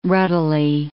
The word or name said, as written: ruddily